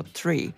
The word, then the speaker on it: Three: Proszę Państwa, o nazwie Three, inaczej wymawianej bez H, czyli drzewo Three.